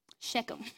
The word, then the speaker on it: Shechem: How do you say that? – Shechem. – Shechem.